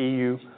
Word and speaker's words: Yiwu: This little city called Yiwu, it's also pronounced EU, like the United, like European Union, EU.